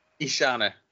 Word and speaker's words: Ishana: Ishana. – Ishana of the Dead. – I'm probably mispronouncing her name. – Probably not. That sounds about right.